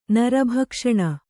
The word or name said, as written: ನರಭಕ್ಷಣ